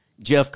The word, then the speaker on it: Geoff Collins: Is that how you say it? Geoff Collins? – Jeff Collins.